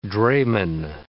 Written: drayman

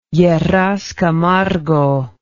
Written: Lleras Camargo

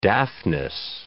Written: Daphnis

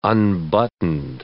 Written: unbuttoned

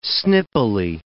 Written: snippily

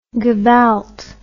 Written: gevalt

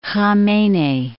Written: Khamenei

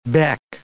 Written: beck

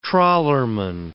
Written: trawlerman

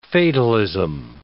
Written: fatalism

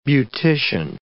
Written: beautician

